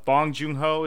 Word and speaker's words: Bong Joon-ho: And it didn't, but it was still very, obviously, Bong Joon-ho, is that how you say it? – Mm-hmm.